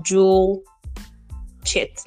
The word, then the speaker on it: Joel Chit: So, well, my name is just pronounced Jewel Chit, like July and Chit.